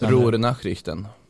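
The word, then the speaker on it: Ruhr Nachrichten: Rornachrichten. – Ja, precis. – Nu sätter jag inte uttalet. – Rornachrichten. – Tack.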